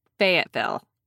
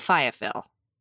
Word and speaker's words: Fayetteville: I am very sorry, Fayetteville, not Fayetteville. – Not Loserville. – Fayetteville. That was never my words.